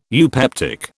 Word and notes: eupeptic: British English

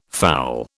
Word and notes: fowl: British English

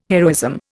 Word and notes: heroism: Indian

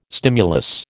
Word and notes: stimulus: American English

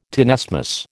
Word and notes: tenesmus: American English

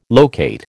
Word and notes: locate: American English

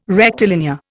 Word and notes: rectilinear: Indian

